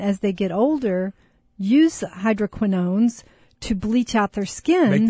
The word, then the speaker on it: hydroquinones: All right, so you want to talk about hydroquinones, right? – Hydroquinones. Is that how I yeah, that's how you say it.